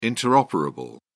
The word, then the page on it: interoperable: British English